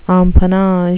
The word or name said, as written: empennage